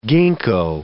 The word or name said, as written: ginkgo